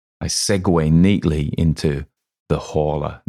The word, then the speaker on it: Horla: Now, one of the things I've got to say is in French, it is le horla. But I decided to pronounce it with the H because it's a completely made up word anyway. So I can pronounce it how I want.